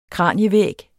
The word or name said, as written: kranievæg